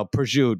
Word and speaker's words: prosciutto: You know, prosciutto. You don't pronounce the o.